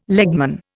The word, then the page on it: legman: Indian